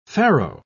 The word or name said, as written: pharaoh